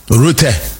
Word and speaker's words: Rutte: Rutte, I believe, is the proper pronunciation.